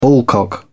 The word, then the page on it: ballcock: British English